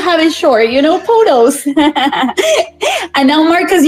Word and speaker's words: POTOS: POTOS.